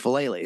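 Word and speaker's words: Faalele: I believe Falele is how you say it.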